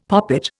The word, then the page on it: poppet: Indian